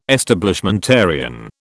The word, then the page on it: Establishmentarian: British English